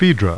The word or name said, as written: PHAEDRA